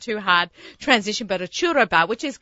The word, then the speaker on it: churro: it's pronounced churro. – Right. – Well, right? – That's pretty good. Churro. – It's Spanish. Mexican. – I have my housekeeper. She taught me that I'm saying it wrong. I told her I have an Australian accent. You know, I have an Australian accent, so I'm not going to say it right. In any case, so, but she said churro. Like she got me to roll my R's.